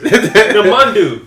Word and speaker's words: Namandu: Namandu is spelled N-A-M-A-N-D-U. – Okay. That's dope. Why would I be saying Richard? – Because I can't pronounce it. – Namandu.